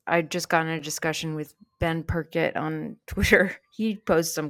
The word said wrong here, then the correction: Perkert: Ben Perkett, Perkert. Wow, I've been saying it wrong all this time.